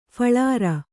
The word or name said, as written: ಫಳಾರ